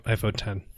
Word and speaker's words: iPhone X: Nope, iPhone 10. It's not X, it's 10.